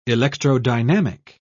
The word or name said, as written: electrodynamic